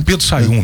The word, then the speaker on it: Sahium: Só o H, né? – É, só o H que ele é, porque o H ele é meio afônico, mudo, né? – Ele é mudo, né? – Então você sabia o Pedro Sayum mesmo? Pedro Sayum, S-A-H-I-U-M.